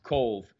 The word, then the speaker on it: Cobh: Now it's spelt in Irish Gaelic, C-O-B-H, but it's pronounced Cove.